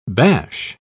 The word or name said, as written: bash